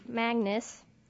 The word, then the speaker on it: Magnus: Well, there's Magnus, rhymes with swagness.